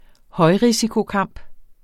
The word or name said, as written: højrisikokamp